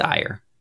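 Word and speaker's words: Aire: It's A-I-R-E, and I never knew how to pronounce that. So it's Ire.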